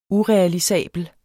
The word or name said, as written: UREALISABLE